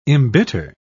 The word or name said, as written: embitter